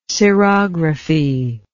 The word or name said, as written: cerography